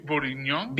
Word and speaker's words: bourguignon: Beef bourguignon.